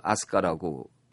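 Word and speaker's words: 아스카 (飛鳥): 그 거기도 아스카라고 그랬는데 여기는 날빛자에다가 세조자를 써서 그 비조라고 쓰고 그 아스카라고 발음을 해요.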